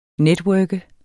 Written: networker

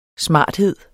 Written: smarthed

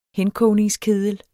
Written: henkogningskedel

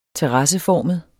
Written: terrasseformet

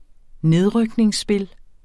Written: nedrykningsspil

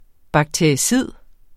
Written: BAKTERICID

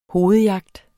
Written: hovedjagt

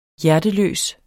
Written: hjerteløs

